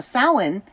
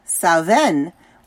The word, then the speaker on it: Samhain: Now, Samhain with the W sound is Irish. Sauven with the V sound is Scottish.